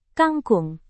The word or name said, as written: kangkung